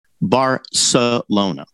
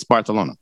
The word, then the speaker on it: Barcelona: N-O-R-S-E Dakota. – I'm just telling you, I just Googled it. The correct pronunciation of Barcelona in Catalan. – Okay. – Not Spanish. But yes. Catalan is Barcelona. – Okay. – In Spanish, it's Barcelona.